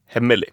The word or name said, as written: hemmelig